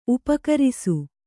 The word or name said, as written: ಉಪಕರಿಸು